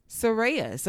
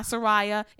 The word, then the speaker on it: Soraya: People say it's not Soraya, it's Soraya. I think I said her name right. I was butchering it saying Soraya, but it was Soraya. So I'm proud to say I had it right the first time before correcting myself, then undoing it.